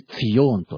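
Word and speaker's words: ツ ヨ ーン (ציון): アの、ね、娘のそう、名前、ションちゃんいるんですけども、シオン、ヘブライ語では、むしろ、ツヨーンというふうに言います。ツヨーン、ツァディ、とか、ま、ツァディに、ユッドに、ユッドが二つ続くんでしたかね。で、それで、最後に、ヌン、という、確かヘブライ語、タディ、ユッド、バブ、ヌンですね。それでツヨーンというふうに発音します。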